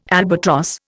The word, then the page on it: albatross: Indian